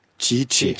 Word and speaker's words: Tite: Non, mais écoutez, je vais vous aider un petit peu à le prononcer. Primeiros convocados do Tite. Tite.